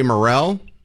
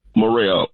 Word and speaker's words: Murrell: Charles Murray Morrell? – Morrell. – How do you spell that? – M-U-R-R-E-L-L.